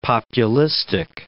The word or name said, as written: populistic